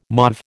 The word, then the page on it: mauve: American English